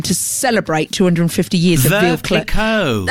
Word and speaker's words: Veuve Clicquot: Exactly what I said, Veuve Clicquot. Is that how you say it? – Yeah, Veuve Clicquot. Yeah. – Are you sure? – Yeah. – I've been calling it Veuve Click all my life.